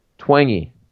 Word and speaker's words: Twenge: Twenge. T-W-E-N-G-E, and that's actually how you pronounce it. – Okay. – You would think it's like twinge or twang.